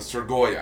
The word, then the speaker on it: Strigoi: You have the Strigori? Is that right? Vampires? – I'm actually not 100% sure how it's pronounced because I only ever read it. – the Strigoi, I believe they call them the Strigoi.